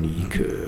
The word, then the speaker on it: Nike: Und das wird dann anders ausgesprochen. Nike, so griechisch.